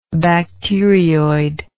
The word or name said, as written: bacterioid